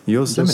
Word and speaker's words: Yosemite: Ja, und äh, 2D-mäßig kann man natürlich auch einfach auf die Josemite Beta. Yosemite, jetzt habe ich schon wieder falsch gesagt. – Yosemite, Yosemite Beta updaten.